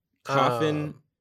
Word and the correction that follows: Coffee Stain Studios: Wait, I said that wrong. Coffee Stain Studios.